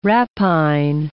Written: rapine

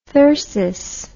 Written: thyrsis

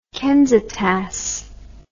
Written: Kensitas

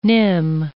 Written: nim

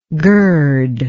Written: girdingly

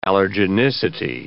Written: allergenicity

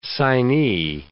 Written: signee

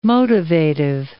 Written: motivative

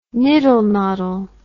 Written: niddle noddle

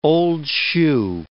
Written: old-shoe

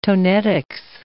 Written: tonetics

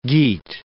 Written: Geat

